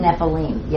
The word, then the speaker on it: Nephilim: It is Nephilim.